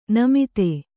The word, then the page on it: ನೇಮಿತ: Kannada